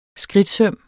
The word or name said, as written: skridtsøm